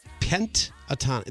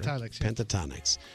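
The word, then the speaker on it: Pentatonix: Pentatonics. That's it. We're saying it right. – Pentatonics.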